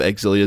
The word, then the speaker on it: Xillia: All I'm saying is, even when I was playing through Tales of Xillia, Tales of Exilia, Xillia, whatever, I've heard it pronounced both ways.